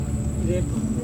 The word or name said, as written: repa